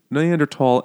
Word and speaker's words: Neanderthal: Pronunciation note, Neanderthal and Neanderthal are both correct, but one is more pedantic, and I will pick that one.